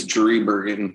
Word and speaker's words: Driebergen: Yeah, I believe it's Driebergen. – Yeah, Ben Driebergen, awesome dude.